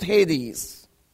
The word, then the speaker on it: Hades: You will go down in Hades. Or some will pronounce it Hades.